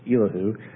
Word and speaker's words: Elihu: And then we heard from a younger man, a younger friend of Job, Elihu. That's how I'm going to pronounce it. If anybody has a better way, that's how. Feel free to use it. I'll gladly adopt it. Elihu.